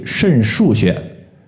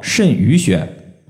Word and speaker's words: 肾俞穴: 想要补肾，在这里我们首推肾腧穴。肾腧穴呢，我就发现有一些朋友啊，他可能是刚接触中医，把这个穴位的读音读作肾俞穴。这个俞就是俞敏洪的俞，当然它是一个多音字。如果你要搞清楚它的一个意思的话，我相信你就不会再读错了。